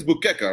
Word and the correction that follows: Buchecha: right? That sounds better. – No, Buchecha.